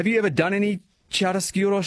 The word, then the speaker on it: chiaroscuro: just remember Chiaroscuro.